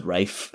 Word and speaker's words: Ralph: you might be cancelled for calling him Ralph. I think they pronounced it Rafe.